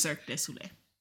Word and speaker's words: Cirque du Soleil: The correct pronunciation is Cirque du Soleil. – How did I say it? – Cirque du Soleil. He was saying it wrong on purpose.